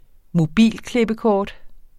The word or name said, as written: mobilklippekort